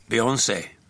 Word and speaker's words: Beyoncé: Du är inte helt säker på vilket av dessa två uttal som egentligen är det rätta. Beyoncé. Eller Beyoncé. Beyoncé heter det.